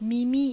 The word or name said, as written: Mimi